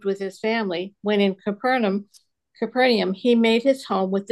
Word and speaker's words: Capernaum: Capernaum? – Capernaum, either one's correct.